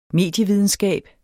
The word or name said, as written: medievidenskab